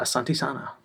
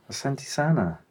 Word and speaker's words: Asante sana: Uh, Asante Sana. Asante Sana.